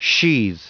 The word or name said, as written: sheathe